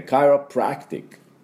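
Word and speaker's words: chiropractic: で、第二、アクセントですから、そんなに強く、カイロプラクティックっていうふうに言いませんので、カイロの方が、アクセントがありますね。このカイのところですね。で、カイロプラクティック。ちょっと軽めに。でも音は A、おみやです。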